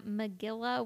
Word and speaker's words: Magilla: Magilla. – Oh, it rhymes with gorilla.